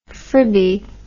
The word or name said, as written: fribby